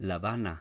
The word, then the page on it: Sylheti: sil-EH-tee